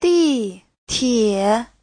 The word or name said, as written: di4tie3